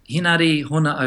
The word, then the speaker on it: Hona: I usually you can get to that through my uh, page, which is Henare Hona O'Brien. Uh, Hona O'Brien.